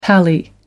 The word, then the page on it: pally: American English